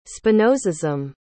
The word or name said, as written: spinozism